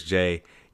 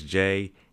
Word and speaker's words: Jay: My name is Las Vegas J. You can follow me on Instagram and YouTube at Las Vegas J, and the J is spelled J-A-Y.